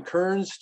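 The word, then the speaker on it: Kerens: And so in Kearns, Texas, and this is another one of those small towns, it's K-E-R-E-N-S, but they pronounce it Kearns.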